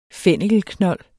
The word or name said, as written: fennikelknold